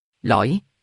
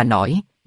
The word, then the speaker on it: lõi: Lõi, ông lão quát. L-O-I lõi, không phải là nổi, bé không học, lớn làm ăn trộm.